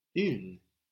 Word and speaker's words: une: For feminine nouns, like la baguette, la bicyclette, la situation, la porte, we have une.